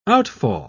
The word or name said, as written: outfall